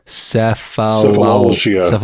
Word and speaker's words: cephalalgia: that'd be the trigeminal autonomic cephalgia. Cephalgia? – Tack. – Tack. Yeah, the TAC. Yeah. Cephalgia. I don't feel like that word should be that hard to pronounce, but it is. Cephalalgia.